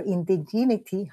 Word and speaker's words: indigeneity: So, but the Puerto Rican people were going to the powwows, and they were recovering their indigeneity. How do you say that? – Indigeneity.